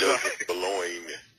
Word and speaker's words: Joseph Boulogne: You, you did butcher it, brother. Oh, Joseph Boulogne, Joseph Boulogne, and Joseph Boulogne came to be known in history as the Chevalier de Saint George Saint Georges.